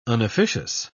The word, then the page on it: unofficious: British English